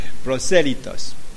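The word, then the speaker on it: prosélitos: Proselitos, sí. Sí, pronunciación de español y no del francés. Proselitos.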